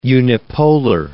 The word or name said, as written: unipolar